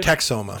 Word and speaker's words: Texoma: Texoma.